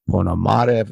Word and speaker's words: Ponomarev: And then in a recent episode on PBS, a gentleman with the his name is Ilya Ponomarev, if I'm pronounced that correctly. He's a Russian dissident who's, I'm sorry? – Close enough.